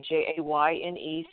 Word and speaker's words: Jayne: So, first of all, my name is spelled with a Y, Jayne Sanders.